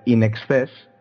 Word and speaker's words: INXS: Había seleccionado una canción de In Excess, escrito INXS, para algunos que no sepan pronunciarlo,